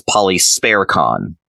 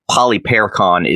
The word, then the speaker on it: Polyperchon: So this is likely the reason that many sources alternatively refer to Polyperchon with the additional S as Polysperchon, because the stem of his name has sperko. But the consensus, especially among the older sources, seems to be that Polyperchon is the accurate form and pronunciation.